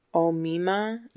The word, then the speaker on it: Omaima: It's Omima. – Yeah, Omima. – Omima. – Omima. – Omima Nelson. O-M-A-I-M-A.